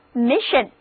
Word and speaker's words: mission: 我们再来看在 miss 词根之后加上 ion 的名词后缀，单词 mission mission 字母组合 sion 读作 shin， read after me 就是 mission。